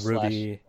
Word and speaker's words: RWBY: Yeah, how do you pronounce it? RWBY or whatever. – Yeah, it has the Ruby.